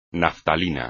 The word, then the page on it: naftalina: espagnol